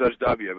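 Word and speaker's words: Ajdabiya: Adabia? – I think it was Ajdabia, but yes.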